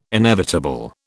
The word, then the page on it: inevitable: British English